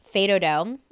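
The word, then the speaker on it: fais do-do: How do you say it? – Fado do. – Fado do? – Yeah, FAISDODO.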